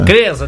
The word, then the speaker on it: CREAS: É CREAS que se pronuncia? – CREAS.